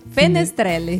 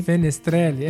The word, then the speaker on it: Fenestrelle: Nada mais se trata do que do Forte de Finestrelle. Fenestrelle. – Fenestrelle, é. é. Forte de Fenestrelle.